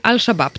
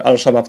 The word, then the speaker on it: Al-Shabaab: Panie Marku, mam nadzieję, że e, pytanie zostało odpowiedź pan uzyskał, natomiast jedziemy dalej, bo tutaj Al-Shabaab, tak powinno się wypowiadać? – No tak, tak chyba będzie najbardziej. Bo się pisze aż szabab i stąd moja wątpliwość. – Polska, po w polskiej pisowni pisze się aż szabab, ale w mm, międzynarodowej na miejscu, gdyby pani powiedziała al szabab, to wszyscy by zrozumieli o co, o co chodzi.